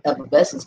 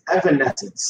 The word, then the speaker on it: Evanescence: Y'all both butchering their name. It's Evanescence. – Oh, Evanescence. – I thought it was Evanescence, honestly.